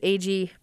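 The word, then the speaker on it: Agee: Well, I love the James, I'm going to say it wrong, and people are going to know, A.G., thank you, the James A.G.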